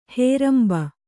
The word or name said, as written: ಹೇರಂಬು